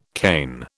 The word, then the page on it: cane: British English